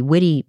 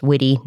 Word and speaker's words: Witte: One of our listeners who grew up in San Antonio in Texas very kindly told us that we've been pronouncing the Witte Museum incorrectly. It's pronounced Witte, not Wit. So we will keep that in mind for going forward. – Hopefully we get it right. It's spelled W-I-T-T-E, which seems like it'd be Wit or maybe White, but I would have never guessed Witte.